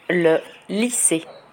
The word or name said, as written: le lycee